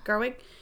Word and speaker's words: Gerwig: This is Greta Gerwig. Is that how you say her name? – Gerwig.